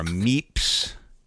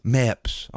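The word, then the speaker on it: Mepps: Is it Meeps? – I don't know what you're talking about. – Meps. Oh, my apologies. You could be running a Meps.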